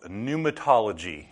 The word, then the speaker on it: pneumatology: Now, the study of the doctrine of the Holy Spirit is called pneumatology, and we'll spell that out for you because it's quite an impressive word. It has a P on the front of it that is silent.